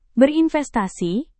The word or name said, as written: berinvestasi